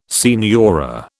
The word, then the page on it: signora: British English